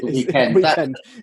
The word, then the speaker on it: The Weeknd: I'm seeing virtual concerts with Travis Scott and The Weeknd. Is that how you pronounce? He's knocked all his... – It's called The Weeknd.